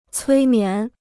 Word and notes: 催眠: cuī mián

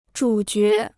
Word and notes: 主角: zhǔ jué

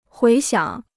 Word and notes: 回想: huí xiǎng

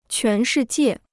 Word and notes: 全世界: quán shì jiè

